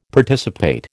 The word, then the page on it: Participate: American English